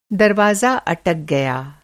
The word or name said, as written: अटक